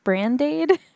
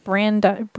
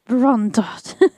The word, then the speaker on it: brandade: Brandade. – Brandade. – Brandade. Oh, it's a dish. Well, duh. – Oh, it's almost like a... – Oh, it's French. I'm sorry I butchered that so bad. Brondot.